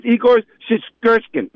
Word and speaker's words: Shishkin: I really butcher his name, but Igor Shishkin. That's how you say it. There you go.